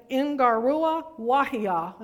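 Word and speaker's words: Ngaruawahia: And this is a hard word to pronounce in Ingarua Wahia, and that's all one word, New Zealand.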